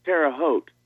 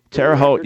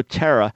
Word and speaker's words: Terre Haute: It's Terre Haute. – Terre Haute. Yeah, I concentrated on getting the first word right. Because I was going to say Terry. I thought, no, Terra. Terra, and I mispronounced it, man. I blew it again.